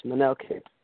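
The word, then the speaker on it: Manel Kape: I believe it's Manel Cape. Is that how you pronounce it? – Yes, Manel Cape.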